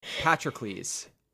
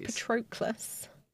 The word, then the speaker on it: Patroclus: Patrocles. That's how you say it. – Is that how you say it? – Yes. – Why did I always think it was Patroclus? – It's that O, but I'm pretty sure it's Patrocles. Makes more sense.